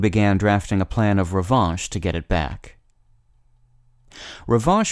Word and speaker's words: revanche: The word of the day for February 27th is revanche, spelled R-E-V-A-N-C-H-E. The N isn't pronounced, but the vowel is nasalized, revanche.